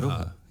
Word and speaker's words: Aroha: And so A isn't A. A is A. And is for, not for apple, but for Aroha. – Aroha, yeah. – And the picture is of? – A heart. Aroha is love.